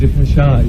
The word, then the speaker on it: Depeche: Yeah, it's quite embarrassing, actually, because, like, being called a French name over there, when we pronounce it wrong, and you go over there and you're saying to them, we pronounce it Depeche, and they say, no, yeah.